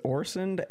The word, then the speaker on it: Orsund: So it is Orsund, as you see it, just the D is silent.